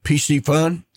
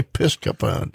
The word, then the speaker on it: Piscifun: He's also public relations and marketing media for TideWe and Piscifun. And he, I said it right. You said it. I said it yep. right. I didn't say Pisca fund, PC fund.